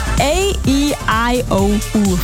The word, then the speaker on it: AEIOU: Wird nur Englisch ausgesprochen. A-E-I-O-U von Oliver Heldens.